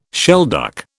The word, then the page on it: shelduck: British English